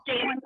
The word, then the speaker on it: Jaelin: No, it's at Jalen Palmer, and my name is spelled J-A-E-L-I-N.